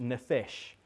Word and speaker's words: nephesh: Now, the word that is used there is pronounced nephesh.